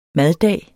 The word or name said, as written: maddag